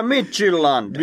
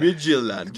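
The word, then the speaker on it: Midtjylland: Viime vuoden mestari Midjylland kohtaa. Niin viime... Lausutaankohan noin? Kyllä, Midgilland. Midgilland. Kyllä, ihan juurikin. Näin olen katsonut sen Sanna-kirjasta.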